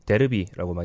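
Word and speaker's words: Derby: 이 더비라는 말 자체가 영어기 때문에 영국에서는 당연히 더비라고 부르는데 다른 나라에서도 자기 나라 발음으로 보통 부릅니다. 그래서 음. 이탈리아나 스페인에서는 데르비라고 많이 하고요. 독일에서는 어, 발음이 비슷해서 더비라고 하는 경우가 많은 것 같아요.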